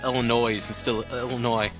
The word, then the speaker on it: Illinois: The S is silent. It's a silent S. – Illinois.